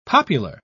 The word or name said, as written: popular